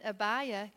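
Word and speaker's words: Abijah: And Abiah, and that's the way to pronounce it in the Bible, so in the program, Abiah.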